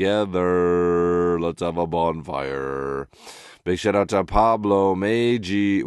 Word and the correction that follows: Pablo Maggi: Wait. Hold on. I'm going to say it right. Joe DiMaggio. Maggi. Pablo Maggi, a.k.a.